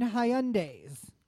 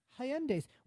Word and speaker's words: Hyundai: Hyundais, which, by the way, have the highest customer loyalty of any brand of car. – You mean Hyundais? – Hyundais. How do you say it? – Hyundai. – Hyundai.